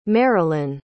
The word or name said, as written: maralyn